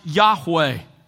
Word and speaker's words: Yahweh: pronounced Yahweh.